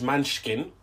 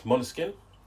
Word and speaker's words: Måneskin: So because Måneskin won, which is the first time I've actually worked out how to say their name, because everyone says different ways. There's Måneskin, there's Manskin, but it's Måneskin.